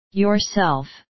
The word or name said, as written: yourself